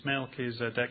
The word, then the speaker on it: Melchizedek: I think he can imagine the church hearing this book being read, and he can hear people hearing this word Melchizedek, which is Melchizedek. It's four syllables, it's long, it starts with M, it's a name, it's weird.